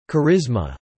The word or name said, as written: charisma